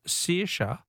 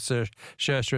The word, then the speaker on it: Saoirse: Helen from Footscray says, Phil, the Irish name Saoirse is pronounced either Saoirse or Saoirse, depending on which county you come from.